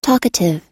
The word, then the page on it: talkative: American English